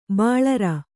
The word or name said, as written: ಬೋಳರೆ